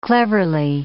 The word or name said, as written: cleverly